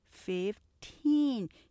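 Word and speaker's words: fifteen: 15는 15 teen 이음절이지만 뒤에 액센트 강조하는 거 기억하시면 정확한 발음 하실 수 있을 거예요.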